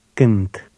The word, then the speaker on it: Cânt: Number 18 contains a letter that looks like an I with a hat. This is pronounced U. – Listen and repeat number 18. – Cânt. Cânt. – This sound is common in Romanian.